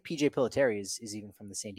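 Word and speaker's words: Pilittere: Pilateri, you got it. Good pronunciation. There you go.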